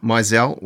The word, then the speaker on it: Meisel: And when you become, you know, you get Stephen Meisel, have I said it right? – Meisel.